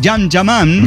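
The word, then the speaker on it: Can Yaman: Acá la Mafis dice que el desmadre que se armó con el turco Cam Yaman, pronunciado bien Yam Yaman, se escribe así, pero se pronuncia, es una cosa turca.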